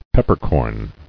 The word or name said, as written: Peppercorn